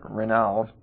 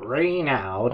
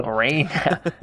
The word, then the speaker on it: Reynaud: He returns back to France later that day to discover that the French Prime Minister, Renault, is how it's spelled. – Reynaud. – Reynaud. That's how you say it in French. – I think it's Renaud.